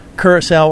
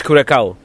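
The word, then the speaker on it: Curaçao: Curacao or Curacao? – I always thought it was the Blue Caraco.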